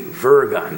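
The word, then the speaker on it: wyrgan: Worry, W-O-R-R-Y, comes from the Anglo-Saxon word virgon, which is spelled W-R-Y-G-O-N, but it's spelled, the W is pronounced V, virgon, W-R-Y-G-O-N.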